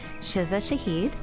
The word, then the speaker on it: Shiza Shahid: Today, we are interviewing Shiza Shahid. Am I saying that right? – Yes.